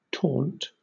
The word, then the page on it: taunt: British English